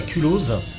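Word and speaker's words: Culoz: Alors pour la troisième année consécutive, le Fest va se dérouler au Phaéton à Culose avec un Z qui se prononce.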